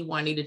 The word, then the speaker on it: Juanita: Instagram is The Juanita Charles. My name is spelled J-U-A-N-I-T-A, but The Juanita Charles.